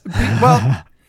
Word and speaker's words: Bayeux: Well, no, like, the two papers I actually wrote this week were on the Bayou Tapestry. – Oh, yeah. You sure you're saying that right? – Yes. Bayeux.